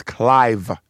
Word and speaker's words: Clive: But my name is Clive, C-L-I-V-E, like Clive Davis, who gave Whitney Houston, you know, her big break. – Yeah. But could people not pronounce Clive? – Right. – They couldn't pronounce it.